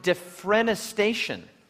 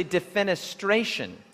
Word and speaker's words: defenestration: And um, my attempt to be clever backfired a little bit last Sabbath because I, I kept pronouncing the word defrenestation, But the word is actually defenestration.